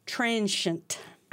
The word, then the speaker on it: transient: There's no Z, but it's transient. – Transient? – Transient. – Oh, my. – The last syllable spelled S-H-E-N-T.